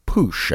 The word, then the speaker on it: Puech: They turned me on to an artist called Jacques Pouche, I believe is how you pronounce it, P-U-E-C-H, and he is a French musician.